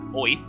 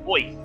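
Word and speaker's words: Oerth: It's called Oyth. You know, I just thought you jumped up or something. Went Oi. – No. It's Oi. As in a New Yorker pronouncing the word Earth. Oi.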